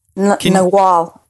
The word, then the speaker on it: nagual: Now, in the book, he's referred to as hopefully I get the pronunciation, a Nagual man? – Nawal. Yeah, Nawal.